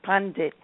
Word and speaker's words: Pandit: Pandit is really, it's pronounced Pandit, and it comes from Pan.